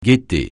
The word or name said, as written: guetter